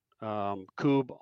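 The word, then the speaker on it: Kubb: There's a great LA Coob Club. They're all over. And you just need to search Coob on social media and you're going to find something.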